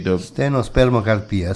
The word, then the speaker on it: stenospermocarpia: E c'ha un nome lungo, io spero di pronunciarlo bene, si dice stenospermocarpia, penso che stenospermocarpia, sì, sembra uno scioglilingue.